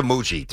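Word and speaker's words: Mougey: But Darren, I don't know if I should say Moogie or Moogie. You know, I'm going to go back to Moogie. Too many people had a soft G last night.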